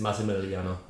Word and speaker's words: Massimiliano: It's Massimiliano. Oh. So like SS.